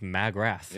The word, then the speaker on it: Magrath: I said, you mean Mark McGrath? – And I was like, no, I think it's pronounced Magrath.